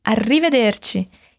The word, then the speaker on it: arrivederci: Arrivederci. E esse ti do final é ti de tia mesmo. O CI em italiano tem essa pronúncia, ti.